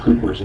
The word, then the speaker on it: Cowper: Cowper's, pronounced Cooper's in English, but Cowper's.